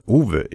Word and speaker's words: Ouwe: Beide stammen aus dem Westerwald und deshalb heißt ihre Bäckerei auch nicht Ofen, sondern Uwe. Ich weiß nicht genau, ob ich es richtig ausgesprochen habe, aber geschrieben wird es O-U-W-E.